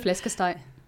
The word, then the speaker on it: Flæskesteg: Ein typisches Gericht in Dänemark ist Flæskesteg. Habe ich es richtig ausgesprochen? Wahrscheinlich nicht genug genuschelt. Nee, Flæskesteg. – Flæskesteg, so sage ich ja, nicht genug genuschelt.